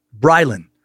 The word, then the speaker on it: Brylan: I started listening to your podcast on Spotify through the recommendation of my brother, Brylan, pronounced like fry and then land without the D.